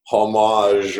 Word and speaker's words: homage: Wow, that's like the ultimate, you know, homage or whatever. – That is the word. You picked the exact word there, homage. And you even did it without the H pronounced right there.